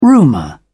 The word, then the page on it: rumour: British English